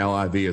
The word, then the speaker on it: LIV: Why they're calling it you're actually pronouncing the LIV as a word.